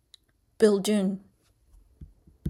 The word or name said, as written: Bilguun